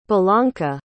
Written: bellanca

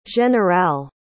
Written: generale

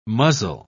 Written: muzzle

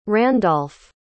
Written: Randolf

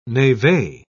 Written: neve

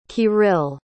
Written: kirill

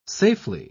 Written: safely

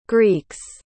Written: greeks

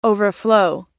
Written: overflow

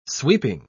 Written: sweeping

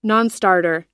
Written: nonstarter

